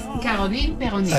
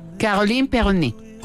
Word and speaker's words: Caroline Peyronet: Es el seudónimo de Caroline Peyronet. Usted que es la que habla francés. No sé si lo pronuncié bien, ayúdeme. ¿Caroline qué? – Es Caroline Peyronet.